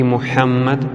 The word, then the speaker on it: Мухаммад: Немає Творця, окрім Бога, і Мухаммад – його пророк. Зверніть увагу, що ім'я пророка Мухаммад необхідно вимовляти правильно, інакше свідчення не будуть дійсними. Літеру «Ха» в цьому імені необхідно промовляти м'яко, з придихом.